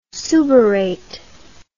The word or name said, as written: suberate